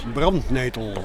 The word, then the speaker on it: Brandnetel: How do you say it in Dutch? – Brandnetel. – Brandnetel, yeah. – Brandnetel. Brand. Fire nettle.